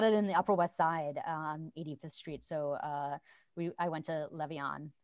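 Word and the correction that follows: Levain: Not Levion, I always mispronounce it. That bakery with the cookies that are like half baked, that are like giant. – It sounds Levon. Good. – Levon, there we go.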